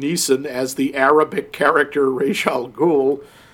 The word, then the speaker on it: Ra's al Ghul: Well, but it's Ra's, R-A-apostrophe-S. So it's like, oh, Ra, like the sun god. It's Ra's al Ghul.